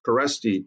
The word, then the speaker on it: Kereszti: Producer William Sommer, a great friend of mine, has trusted me to interview our guest, Tom Kereszti.